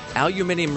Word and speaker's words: aluminium: Well, and it's also correct, by the way. Aluminium really is the correct pronunciation.